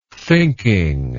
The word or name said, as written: thinking